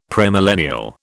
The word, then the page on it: premillennial: British English